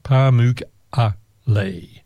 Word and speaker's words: Pamukkale: It's spelled Pamukkale, and it's pronounced Pamukkale.